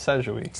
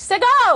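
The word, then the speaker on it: Segoe: And how you pronounce Sejui. – Sego!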